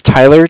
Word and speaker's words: Tyler: My name is Tyler, T-Y-L-E-R, and okay. – I'm calling with Cardiff. It's spelled C-A-R-D-I-F-F, as in Frank.